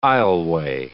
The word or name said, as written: aisleway